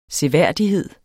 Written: seværdighed

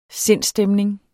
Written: sindsstemning

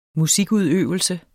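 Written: musikudøvelse

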